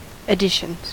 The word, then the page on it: additions: American English